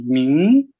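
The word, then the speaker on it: míng: «Мин». Так оно и произносится вторым тоном.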